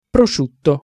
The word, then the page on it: prosciutto: Italian